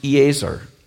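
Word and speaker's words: Eazer (עֵזֶר): The word helper is a Hebrew word that's pronounced Eazer.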